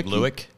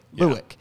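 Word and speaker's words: Lueck: Did you help crack the McKenzie, how do you say the last name? – McKenzie Lewick? – Lewick. – Yeah.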